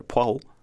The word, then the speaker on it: Poirot: So for the word Poirot itself, you could almost remove that R and just say pois, pois, o, o, pois, and then put them together, Poirot, Poirot.